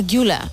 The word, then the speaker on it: Güler: Creo que Arda es Arda Güler. – Ah, Güler.